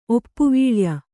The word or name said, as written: ಒಪ್ಪುವೀಳ್ಯ